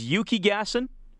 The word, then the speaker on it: Yukigassen: Now, the sport, if I remember how to say it, is Yukigassen? – Yeah, that's it.